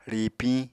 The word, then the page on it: ríipiñ: Apatani